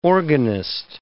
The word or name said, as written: organist